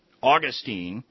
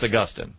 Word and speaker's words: Augustine: Yeah, you could walk into any university in America where they teach philosophy and theology and pronounce it Augustine, and the professor will correct you. So and say it's Augustine.